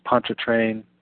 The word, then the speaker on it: Pontchartrain: Sorry about this, Pontchartrain, Pontchartrain.